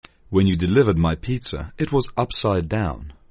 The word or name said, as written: upside down